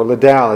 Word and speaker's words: Liddell: One of my personal heroes is Eric Liddell, or Liddell as some would pronounce his last name, a Scottish runner who won a gold medal at the 1924 Olympics in Paris.